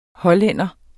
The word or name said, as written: hollænder